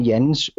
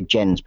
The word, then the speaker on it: Jens: And I pronounce the name Jens in Danish because it's a Danish name. I am Danish. A lot of people, English-speaking people, will say Jens because it's J-E-N-S.